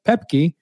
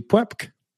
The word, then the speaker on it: Pueppke: Chris Pepke, which we actually, full disclosure, we had to ask him how to pronounce his last name because it's spelled P-U-E-P-P-K-E. And to me, that'd be Pepke, but it's Pepke.